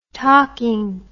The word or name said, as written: talking